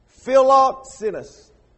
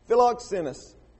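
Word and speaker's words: Philoxenos (φιλόξενος): It's P-H-I-L-O-Z-E-N-O-S. I wouldn't try to pronounce it then, but I'm going to try today. Philoxenos. Philoxenos. And remember, we talked about the P-H-I-L-O. It sounds a lot like Philadelphia, right? The city of brotherly love.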